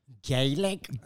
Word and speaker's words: Gaelic: Gaelic? – Gaelic. They spell it G-A-E-L-I-C, but you could spell it G-A-Y-L-I-C-K. Yeah. Gaelic.